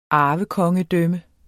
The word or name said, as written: arvekongedømme